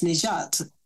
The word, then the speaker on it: Nagat: But I have a name called Nagat. Nagat in Arabic is pronounced Nijat, and Nijat means to rescue.